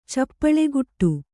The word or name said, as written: ಚಪ್ಪಳೆಗುಟ್ಟು